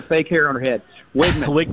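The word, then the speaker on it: Weigman: The E throws things off, because it's spelled W-E-I. – Yeah. – It is Wigman, W-I-G. Just think of there you go.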